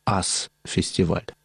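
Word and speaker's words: US Festival: В этом месте уже и до этого проводились рок-фестивали, которые имели название «Ас-фестиваль». Пишется как «US-фестиваль».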